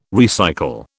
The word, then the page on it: recycle: British English